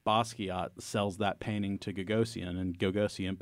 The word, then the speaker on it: Gagosian: Am I pronouncing his name right? – Yes. – Okay, cool. So Larry Gagosian has a string of very high-profile galleries, New York, Athens, London, etc.